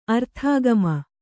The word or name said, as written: ಅರ್ಥಾಗಮ